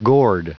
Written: gourd

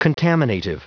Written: contaminative

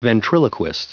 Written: ventriloquist

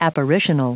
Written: apparitional

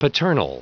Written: paternal

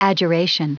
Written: adjuration

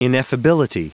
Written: ineffability

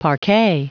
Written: parquet

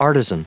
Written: artisan

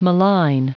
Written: malign

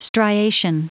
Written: striation

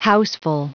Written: houseful

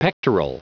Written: pectoral

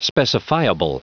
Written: specifiable

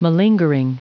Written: malingering

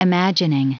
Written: imagining

